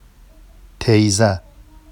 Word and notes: tejza: Vafsi

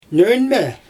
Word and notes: nöñmE: Xyzyl

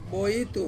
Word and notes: boitu: Ansus

